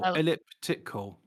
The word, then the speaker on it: elliptical: Ecliptical. – The reason I'm struggling to pronounce this word is I've never seen it before. It's ecliptical. – It's E-double-L-I-P, ellip, and then it's tickle, elliptical.